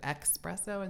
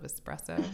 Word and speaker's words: espresso: I would never, ever shame anybody for mispronouncing a word, but there is part of me that cringes a little when I hear espresso instead of espresso.